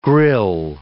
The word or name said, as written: grill